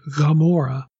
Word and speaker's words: Gomorrah: What if instead we have Har and then Mem Gayin, the other Hebrew consonant that has that G sound, like Gomorrah is actually not spelled with Hebrew G, Gimel. It's spelled with Gayin, Gomorrah. It has that back of the throat G sound.